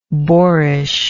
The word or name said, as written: boarishness